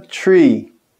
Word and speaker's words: tree: Árvore, a gente fala tree. Tree. O número 3 é o, o som do TH com o R.